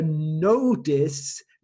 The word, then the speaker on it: notice: Se você está, está escutando como a primeira sílaba é no, a segunda sílaba é tis. É muito dividido assim. Notice. A pronúncia dessa palavra é difícil para brasileiros. Eles têm que entender que você para a primeira sílaba com no. A segunda sílaba, tis. Ou em inglês americano, no this, notice, notice.